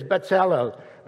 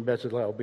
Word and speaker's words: Bezalel: The actual pronunciation is Bezalel, but we're going to go with the American pronunciation Bezalel because it turns my tongue when I try to go the other way.